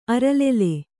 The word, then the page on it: ಅರಲೆಲೆ: aralele